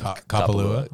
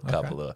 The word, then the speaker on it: Kapalua: Kapalua. – Kapalua.